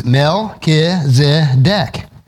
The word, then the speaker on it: Melchizedek: How many of us today have trouble pronouncing Melchizedek? It's Melchizedek.